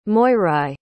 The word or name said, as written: moirai